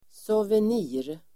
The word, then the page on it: souvenir: [soven'i:r]